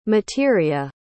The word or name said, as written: materia